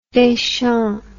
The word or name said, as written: Deschamps